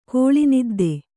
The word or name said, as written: ಕೋಳಿನಿದ್ದೆ